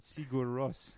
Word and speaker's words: Sigur Rós: Nice pronunciations on Sigur Rós. I don't know how I always just said it. Yeah. – I try to say like Bjork would say it.